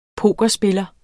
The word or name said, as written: pokerspiller